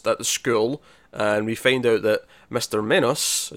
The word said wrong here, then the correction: Minos: am I pronouncing that right? – Minus. – Yeah, Minos.